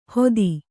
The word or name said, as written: ಹೊದಿ